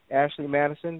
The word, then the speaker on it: Ashley Madison: Ashley Madison. Did I say that right? – Yes.